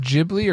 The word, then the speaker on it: Ghibli: Spirited Away, is that pronounced Ghibli or Ghibli? – I thought Studio Ghibli. – I think it's Studio Ghibli. – Okay. Ghibli with a J. – Oh, like not like giblets.